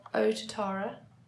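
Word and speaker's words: Otatara: Um, we had the correct pronunciation of O Tatara is O as in go, Tatara Oh, Tatara.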